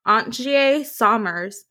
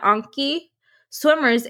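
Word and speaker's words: Antjie Somers: Ankie is spelled A-N-T-J-I-E and Swimmers, S-O-M-E-R-S. So it was like my brain wanted to say Ankie Sommers, but I looked it up and I think I got the pronunciation correct.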